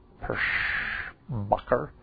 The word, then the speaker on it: Perschbacher: P-E-R-S-C-H-B-A-C-H-E-R, Pirschbacher.